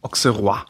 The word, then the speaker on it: Auxerrois: Auxerrois.